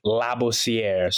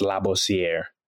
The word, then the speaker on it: Labossiere: So the proper pronunciation is Labossiere. So it's, you're not emphasizing Labosse, you know, so... again, that's kind of Labosse is where the nickname came and stuck with, you know, just growing up in America. But, you know, Haitians, French, it's pronounced Labossiere.